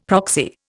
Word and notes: proxy: Indian

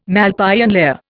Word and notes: Malpighian layer: Indian

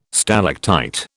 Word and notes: stalactite: British English

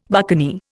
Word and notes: buccaneer: Indian

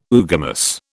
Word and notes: oogamous: British English

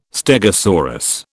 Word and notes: stegosaurus: British English

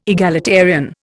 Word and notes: egalitarian: Indian

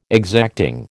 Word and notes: exacting: American English

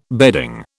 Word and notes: bedding: British English

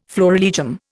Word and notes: florilegium: Indian

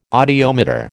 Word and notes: audiometer: American English

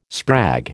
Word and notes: sprag: American English

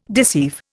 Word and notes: deceive: Indian

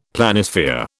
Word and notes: Planisphere: British English

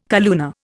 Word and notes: calluna: Indian

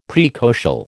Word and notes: praecocial: American English

